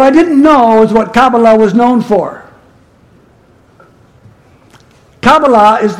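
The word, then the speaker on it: Kabala: Did I say that right? Kabbalah? Theo, I said it right. Okay, Kabbalah.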